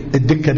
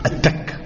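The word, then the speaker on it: التكة: الدكه بالدال، صح؟ لا، نطقها الصحيح بالتاء، وهي لغه عربيه فصحى، التك.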